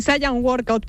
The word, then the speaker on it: Saiyan Workout: Sí, Saiyan Workout, pero lo puedes pronunciar como tú quieras.